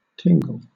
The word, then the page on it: tingle: British English